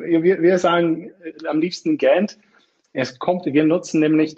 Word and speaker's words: GANDT: Ich hatte jetzt gedacht, vielleicht ist das Unternehmen auch, äh, könnte man es auch Gant aussprechen oder, äh, aber es wird schon, ist schon der englische Begriff Gant, aber du hast dann auch gesagt, dass die einzelnen Buchstaben ja eben auch für etwas stehen.